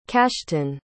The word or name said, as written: cashton